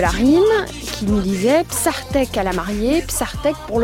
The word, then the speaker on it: bsahtek: On le retranscrit à l'aide de l'alphabet romain, hein, le nôtre, Psartek, B-S-A-H-T-E-K. Le H permet l'aspiration qui n'existe pas dans la prononciation du français, Psartek, et qu'on prononce d'ailleurs légèrement R en français.